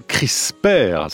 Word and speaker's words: CRISPR: Pascal Cossard, notre invité, avec ce livre chez Odile Jacob, La Nouvelle Microbiologie, des microbiotes au CRISPR.